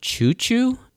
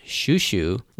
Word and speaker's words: Chouchou: So, I know in the romanization spelling and in the subtitles, it's spelled C-H-O-U-C-H-O-U, and so it's spelled like it's supposed to be sound choo-choo, and I don't know if that's actually how it's pronounced in the English dub, but in Japanese, it's pronounced Shushu, like S-H-U-S-H-U.